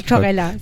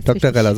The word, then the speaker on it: Doktorella: Oder heißt es Dr. Ella? Das habe ich mich nämlich Doktorella. – Doktorella, so wie Barbarella quasi.